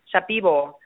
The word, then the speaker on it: Shipibo: Shipibo shaman. – I might be saying that wrong. I don't know how to say it correctly. – I think you're saying it right. It's Shapibo. Shipibo.